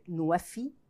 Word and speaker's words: NWFE: It's NWFE. It's pronounced in Arabic, Nwefi.